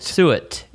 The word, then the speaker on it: suet: Suet? Sweat? Suet. Suet. It's pronounced suet.